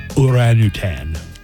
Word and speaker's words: orangutan: And I pronounce it like that because I watched a nature documentary with like David Attenborough and he pronounced it Orangutan.